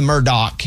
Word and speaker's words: Murdaugh: Raymundo, can you give everybody, first of all, it's the Murdaugh, even though it's spelled Murdaugh.